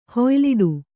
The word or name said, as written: ಹುಯಿಲಿಡು